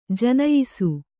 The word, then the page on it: ಜನಯಿಸು: Kannada